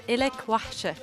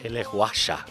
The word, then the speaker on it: Lak Wahsha: With a tune that apparently is spelled kind of weird versus how it's actually pronounced. – I don't know why the spelling is like this. It is pronounced Lak Wahsha.